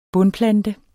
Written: bundplante

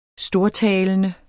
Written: STORTALENDE

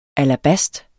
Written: alabast

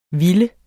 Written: ville

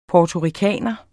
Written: puertoricaner

